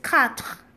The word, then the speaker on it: quatre: Now for this quatre, it may be difficult for most of you or some of you to pronounce it the exact way French the Frenchman pronounces it, but if you're able to try it best. If not, I usually teach my students, especially the little ones, that you can just pronounce it the way you see it, as in cat.